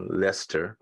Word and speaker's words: Leicester: And if you just maybe can't reach Javon, well, you know, he's in England and Leicester, right? I'm saying it correctly. Yes.